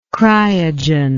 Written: cryogen

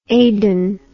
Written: Aidan

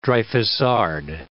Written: Dreyfusard

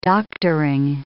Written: doctoring

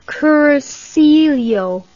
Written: cursillo

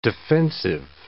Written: defensive

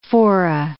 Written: fora

